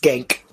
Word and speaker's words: Cenk: It's pronounced Gank.